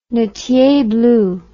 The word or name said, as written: nattier blue